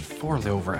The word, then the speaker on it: forlover: I think he needs his farm lover. – It's pronounced Forlover. I don't have time to teach you Danish.